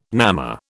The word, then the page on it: namma: British English